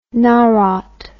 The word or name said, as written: narod